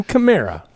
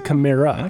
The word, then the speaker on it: Kamara: It's Alvin Kamara. – I think that's like a European pronunciation of it. So it's Alvin Kamara. – It's like the car. It's a Camaro, but it's a Camara.